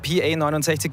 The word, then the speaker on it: PA69: Wir haben mit PA69 geredet über hässliche deutsche Wörter schön machen und ob ich ihren Bandnamen eh richtig ausspreche. – Ich sag PA69. – Correct.